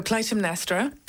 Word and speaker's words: Clytemnestra: So Clytemnestra. – Clytemnestra. – I think some people who are kind of more knowledgeable within it, sometimes they don't pronounce the T.